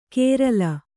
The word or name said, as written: ಕೇರಲ